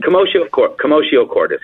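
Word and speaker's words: commotio cordis: Yeah, and he gave commotio Am I saying that right? Camoscio, right? – Camoscio Cordis, correct.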